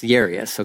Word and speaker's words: Jairus: It says the leader of the local synagogue, whose name is, okay, so in Texas, we would say Jairus, but that's not technically how you say it. It's pronounced with a Y, Jairus, okay?